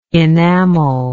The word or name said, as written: enamellist